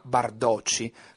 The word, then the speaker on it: Bardotti: Bardotti. Un disco che si chiama Bardocci, diciamo portoghesizzando il suo nome, pronunciandolo un po' in maniera come detto da un brasiliano.